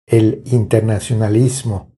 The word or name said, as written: El internacionalismo